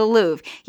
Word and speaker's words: Louvre: The Louvre? – In France. The Louvre.